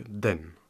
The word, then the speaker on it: 等: Alors, si au Thaï, attendre se prononce donc den en chinois.